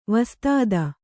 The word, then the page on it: ವಿಸ್ತøತಿ: vistřti